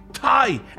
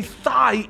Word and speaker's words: Thai: It's been reported that when Ron was dating back in college, he would mispronounce the word Thai, as in Thai food, and say thigh instead.